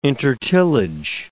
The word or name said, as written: intertillage